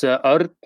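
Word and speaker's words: Örn: It's the same with my name. It's Art.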